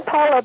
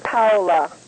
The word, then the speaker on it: Paola: Yeah, it's Paula, but in Italian it's Paola. – Paola. Okay. So I'll say something close, and that'll be good enough. Okay. – You can call me Paula.